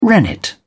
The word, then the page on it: rennet: British English